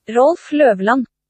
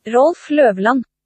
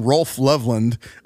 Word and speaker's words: Rolf Løvland: It looks like Loveland, but I know the O is not pronounced that way. So here is, I believe, the pronunciation of it. This is what I found online. – Rolf Løvland. – Okay, that is how to pronounce his name.